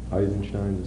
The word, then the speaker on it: Eisenstein: I mean, not the Charlie Chaplin things only, but the old Eisenstein things, or how do you pronounce it, I don't know. Eisenstein, is the old. Okay, it's the same pronunciation, I just wasn't sure.